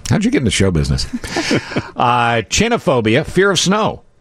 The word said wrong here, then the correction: Chionophobia: Oh, I'm sorry. I pronounced it wrong. Chionophobia. – Jay Leno scares you the most because his chin is the most protruding. – The new Jay. Chionophobia, fear of snow.